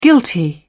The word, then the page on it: guilty: British English